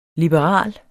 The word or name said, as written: liberal